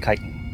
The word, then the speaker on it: chitin: And it's pronounced chitin, or chitinous.